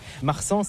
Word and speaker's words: Marsan: Alors je me suis demandé, Marsan, c'est pas votre prénom? – Donc Marsan, c'est bien comme si c'est le mot marchand. Ici on prononce en créole. – Ouais, c'est créole.